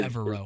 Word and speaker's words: Evero: Um, am I saying that right? Ejiro Avero? Um, Evero, um, is, Evero. is, sort of how we've come to know that his name is pronounced. but um, yeah, it took it took us all out here um, uh, just a, a, little bit to get that right. But yeah, Ejiro Evero, um, you know, his players and, and fellow coaches called him E.